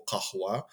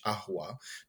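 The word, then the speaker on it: kahwa (قهوة): Oni to zamieniają po prostu bardzo często na g, więc słowo kahwa w standardowym arabskim będzie właśnie brzmiało w ten sposób. W Damaszku, czyli no, w metropolii, jakby nie było, na kawę będą mówić ahwa, czyli nie będą wymawiać w ogóle tego kaf. Natomiast Beduini na pustyni Wadi Ram na przykład będą mówili już gahwa.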